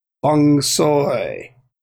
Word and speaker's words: Feng Shui: But if you look in the book, in the pronunciations, it says it's pronounced Feng Soy.